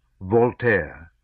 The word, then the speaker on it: Voltaire: A genuine encyclopedist, a kind of Voltaire, with the stress true on the first syllable, he unstintingly copied out thousands of pages.